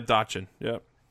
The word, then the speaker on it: Dotchin: And they had, I'm going to butcher his name, Dotchin? – Yeah, Dotchin, yep.